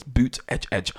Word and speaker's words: Buttigieg: he tells us how to pronounce it. It's Buttigieg. 38-year-old former mayor from South Bend,